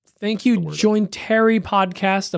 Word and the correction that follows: John and Terry: Am I saying that right? – Jointary. – Terry. I know Terry. – It's John and Terry. Oh, I love those guys. – John and Terry Podcast. I was mispronouncing it.